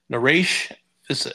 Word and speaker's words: Naresh Vissa: Naresh Vissa.